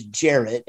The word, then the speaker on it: Jarrett: I don't know about much worse because my first name also is Jarrett and it rhymes with carrot and parrot.